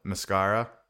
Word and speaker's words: Masskara: The mascara. Is that how you say it? – Yeah, mascara.